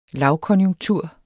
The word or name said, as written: lavkonjunktur